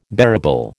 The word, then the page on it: bearable: American English